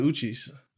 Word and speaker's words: Uchis: Oh, you're talking about Kelly Uchis. – Nigga, you're not even saying it right. – Where are you getting the N in her last name? – I didn't say N.